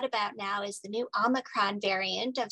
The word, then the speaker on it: Omicron: Omicron,